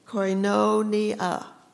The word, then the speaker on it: koinonia: The word in the Greek is koinonia, and it's spelled K-O-I-N-O-N-I-A, and it's most often translated into English as sharing, fellowship, or communion.